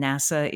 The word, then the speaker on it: NASA: I don't know, and I don't think anyone knows why NACA is pronounced with the letters and NASA is pronounced as a word.